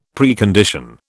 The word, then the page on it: Precondition: British English